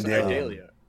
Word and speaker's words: Idalia: That's Idalia.